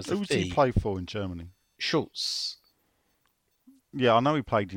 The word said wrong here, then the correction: Schalke: What how are they pronounced? Schalke. Schalke 04.